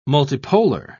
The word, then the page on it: multipolar: British English